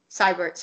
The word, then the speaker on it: Siebert: Siebert spelled S-I-E-B-E-R-T. Siebert, sorry, I pronounced it wrong. I'm so sorry. Wait. – Oh, it's Siebert.